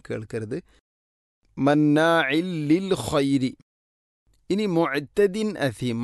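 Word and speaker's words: മുഅ്തദിൻ അസീം (مُعْتَدٍ أَثِيمٍ): തൻവീനു ശേഷം ഹംസ വന്നാൽ ഇൽഹാറാണ് ചെയ്യേണ്ടത്, അവൻ മണിക്കാൻ പാടില്ല. പക്ഷേ നമ്മളൊക്കെ ഓതിപ്പോകുമ്പോൾ മുഅ്തദിൻ നസീം എന്നായിരിക്കും. അധികം ആളുകളും ഓത് ശ്രദ്ധിച്ചു നോക്കുന്നുണ്ടോ? മുഅ്തദിൻ നസീം എന്നായിരിക്കും വായിക്കുക. അത് അറിയുന്ന ആളുകളിൽ പോലും അങ്ങനെ ഉണ്ടാവുന്നു എന്ന കാര്യത്തിൽ ഗൗരവത്തോടെ ശ്രദ്ധിക്കേണ്ട വിഷയമാണത്.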